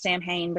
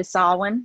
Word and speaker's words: Samhain: And a lot of people look at it, and of course we spell the word like Samhain. And in a lot of horror movies, it's pronounced Samhain, but it's actually Samhain because it's a Celtic word.